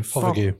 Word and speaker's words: Fothergill: Fothergill.